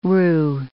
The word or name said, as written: roux